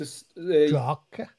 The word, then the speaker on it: Drakar: The trick with Drakar is you need to... – Drakar? – Yeah, exactly. Draw. Like you say draw in English. Drakar.